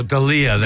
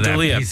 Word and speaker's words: D'Elia: Chris D'Elia? – Oh, D'Elia. D'Elia.